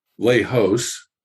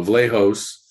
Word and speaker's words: Vlahos: William Vlahos, as in dose. So Vlahos.